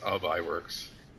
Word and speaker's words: Ub Iwerks: We'll do Oob Works, how do you say his name? – I think it's Ub Iwerks. – Ub Iwerks. Okay, there you go.